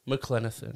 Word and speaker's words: McClenathan: I've known you for over a decade, and I always get nervous when I say your last name, McClenathan.